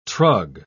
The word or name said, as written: trug